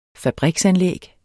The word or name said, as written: fabriksanlæg